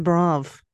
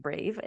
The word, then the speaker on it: brave: You even spelt brave like brav, like the brav. What is that still a thing? – Well, it's the phonetic spelling. So it's actually not pronounced brav, it's pronounced brave.